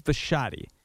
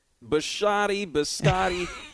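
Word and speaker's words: Bisciotti: He doesn't do a lot of interviews, so a lot of people don't know the proper pronunciation. It's Steve Biscotti. – Biscotti.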